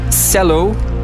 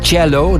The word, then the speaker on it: cello: C-E-L-L-O. En gewoon even voor alle duidelijkheid, je kan dat uitspreken als cello, dat is de oorspronkelijke Italiaanse klank. Maar in het Nederlands is het officieel gewoon cello, dus met de s.